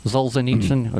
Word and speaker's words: Solzhenitsyn: It's a quote from Soviet dissident, and I hope I pronounce this guy's name right, Alexander Solzhenitsyn. – Solzhenitsyn, okay.